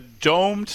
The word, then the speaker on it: domed: it's called the Domed Stadium, not the Doomed Stadium, it's domed.